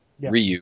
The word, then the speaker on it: Ryu: The first Street Fighter had Ryu, and yes, it's pronounced Ryu. – It's Ryu, not Ryu. Not Ryu. – And I'm okay with somebody says Ryu. I occasionally say Ryu, but it's Ryu.